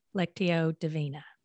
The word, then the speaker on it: Lectio Divina: And so tell everybody, what is Lectio Divina? – Yeah.